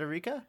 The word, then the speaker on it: Arika: But I did want to talk about Arika, which is the, I think, yeah, I don't know, how do you pronounce their name? Is it Arika? – I believe it's Arika. – Okay, we'll go for it with Arika.